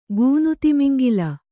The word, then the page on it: ಗೂನುತಿಮಿಂಗಿಲ: gūnu timiŋgila